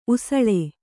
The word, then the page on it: ಉಸಳೆ: Kannada